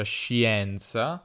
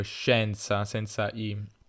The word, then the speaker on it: scienza: Ehm, dunque, questa è una cosa importante e questo succede per tutto, quindi se prendiamo la parola scienza, che tra l'altro non si pronuncia scienza, questo è un errore oppure un regionalismo, so che a Napoli si dice la scienza, la scienza eh, pure anche in altre, in altre regioni, ma anche in Piemonte, in realtà spesso persone più anziane dicono scienza, ma in realtà la I non, non ehm, è lì per una questione etimologica, se non sbaglio, ma non è necessario pronunciarla, dunque scienza senza I.